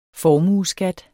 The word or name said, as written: formueskat